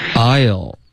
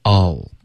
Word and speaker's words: I'll: I'll 这个发音也可以可是我们最好用这个弱读 I'll OK 把它发成 I'll、